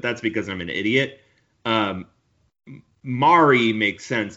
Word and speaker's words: Mari: So her name is Anna, but it's A-N-A. And then it's M-A-R-I. It's pronounced like calamari. Mari. Like, so Anna Mari, and it's Kause, her last name.